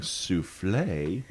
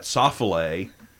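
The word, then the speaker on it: soufflé: So instead of saying souffle, you say that soffle.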